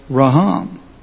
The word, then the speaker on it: raham: And it's and as you look at it, the word is rehem, R-E-H-E-M, R-E-H-E-M. And it's related to a word that's almost spelled the same called raham, R-A-H-A-M. So you have rehem, which means mercy, mercy, merciful, and you have raham.